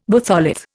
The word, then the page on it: batholith: Indian